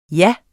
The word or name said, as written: ja